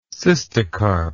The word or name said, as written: cystocarp